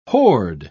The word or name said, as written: hoard